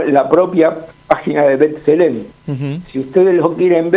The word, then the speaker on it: B'Tselem: Un estudio que hizo un colectivo que se llama Colectivo Feminista Palestino, que está refrendado por B'Tselem.